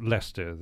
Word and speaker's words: Leicester: This goes way back to my high school days when my name was continually mispronounced, and it was everything from Leicester to Leicester to Leicester to whatever. And when I arrived many years ago in this fair country, I thought, let's stick with the English pronunciation. So Leicester then became the accepted. – I see. So, but it's actually spelled L-E-I-S-T-E-R. When did you first encounter L-E-I-C-E-S-T-E-R?